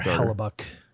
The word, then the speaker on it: Hellebuyck: Hellebuyck, however you say his name. – Is Connor Hellebuyck. – Hellebuyck? – Hellebuyck.